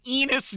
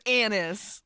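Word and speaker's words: Ennis: I want to call him Enos Del Mar. But they say Anis. – It is Anis.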